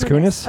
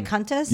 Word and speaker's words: Kunis: It's Kunis. – Oh, did I say Kuntis? – Yeah.